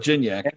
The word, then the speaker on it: Gignac: The Frenchman, Gignanic. – Yeah, you got like four syllables. Like cognac, but Gignac.